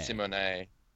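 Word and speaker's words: Symoné: And dropped a little bit of a bombshell, actually, because apparently you're you're supposed to be pronouncing her name Raven Simone. – Simone. Yeah, because it's got an accent on the end. – Wait, so you knew this? – Well, I always wondered why everyone said Simone, because the accent means there should be like Simone or Simone or.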